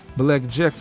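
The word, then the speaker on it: BLK JKS: Black Jacks это произносится, а пишется BLKJTS.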